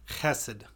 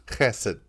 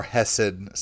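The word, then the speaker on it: Chesed (חסד): How do you pronounce it? – Chesed. – Chesed, yeah. Or hesed, sometimes people say it, or even chesed, C-H-E-S-E-D.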